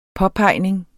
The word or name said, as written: påpegning